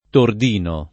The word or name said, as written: Tordino